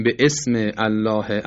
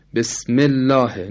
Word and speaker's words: بسم الله: میگیم بسمه، نمیگیم به اسم. بسم الله، نمیگیم به اسم الله.